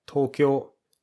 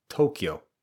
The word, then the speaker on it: Tokyo: One way to answer that is, 僕は東京に行く。And notice here, we pronounce Tokyo like Tokyo, not like it would be pronounced typically in English, which is more like Tokyo. Notice the intonation is quite different.